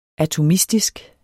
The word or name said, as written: atomistisk